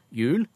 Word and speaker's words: hjul: Mens vi sier jo jul og jul til begge to, men i skrift henger jo gamle, gode H-en igjen.